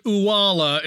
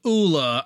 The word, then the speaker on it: Ula: You know, I've heard Uwala instead of Ula.